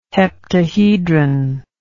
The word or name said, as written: heptahedron